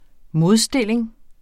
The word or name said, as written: modstilling